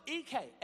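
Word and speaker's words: ek (ἐκ): That word is E, it's spelled E-K, ek.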